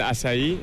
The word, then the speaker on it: açaí: Los boles de acai, lo digo bien, que están muy de moda, ¿no? – Sí, es el açaí, que es una fruta de Brasil.